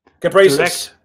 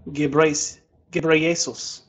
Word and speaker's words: Ghebreyesus: Test. Gebrasis. I'm going to go with Gebrasis. – Director General Dr. Tedros Adhanom Ghebreyesus.